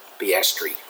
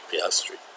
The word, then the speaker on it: Piastri: And F2, we got the young Aussie Piastri, is it? Or how do I pronounce that? – Piastri. – Piastri.